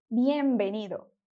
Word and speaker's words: bienvenido: A pronúncia dela é bienvenido. Mais uma vez, bienvenido.